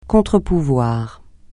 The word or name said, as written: contre-pouvoir